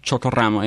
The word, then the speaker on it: Chocoramo: Chocoramo, yeah. Roll your R.